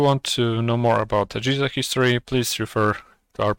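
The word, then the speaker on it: AGESA: EGISA, which stands for AMD Generic Encapsulated Software Architecture, pronounces somewhat like PISA, EGISA.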